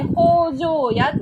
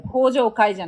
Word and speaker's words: 北条屋: 会社の会で、で、北条屋ってそれで読むんですよ。北条屋。北条会じゃなくて北条屋って読むんですけど。